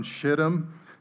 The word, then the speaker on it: Shittim: And what happened from, and I really feel like I'm not allowed to say this word in church, what happened from Shittim, and I promise you, I looked that up to make sure I was pronouncing it correctly, and I'm sorry, maybe in church I should say from Crapham to Gilgal.